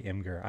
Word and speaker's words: Imgur: I say Imgur. I-M-G-U-R.